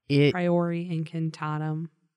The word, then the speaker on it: Priori Incantatum: coming back as he does with the Prior Incantatum. – Priori Incantatum. There's an I on the end.